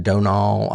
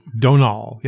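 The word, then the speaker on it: Domhnall: And it is pronounced Donal. I looked that up because it's spelled with an M, and the M is silent. – Donal, yep.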